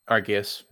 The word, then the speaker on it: Arceus: Arceus, am I pronouncing that correctly? Arceus? – Arceus.